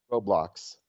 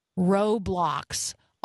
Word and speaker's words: Roblox: There's a war on Roblox. – Roblox.